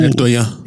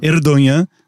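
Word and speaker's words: Erdoğan: Erdogan. – Erdogan.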